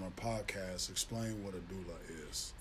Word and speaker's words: doula: actress, and you're also a doula.